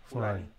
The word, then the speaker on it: Fulani: Right, so it's pronounced Fulani. Fulani. – Okay, Fulani.